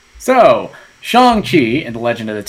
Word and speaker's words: Shang-Chi: I almost mispronounced it. – Shang-Chi.